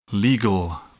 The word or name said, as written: legal